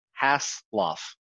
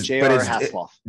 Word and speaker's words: Hasloff: Two syllables. Hasloff. – Okay, Hasloff. Okay. But it's J.R. Hasloff.